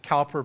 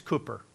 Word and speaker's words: Cowper: One word, and I pronounced his name the same way that you did, Cowper, because it looks like that, C-O-W-P-E-R. It's Cooper. That's the way the Brits pronounce it.